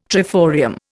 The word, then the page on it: triforium: Indian